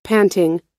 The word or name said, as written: panting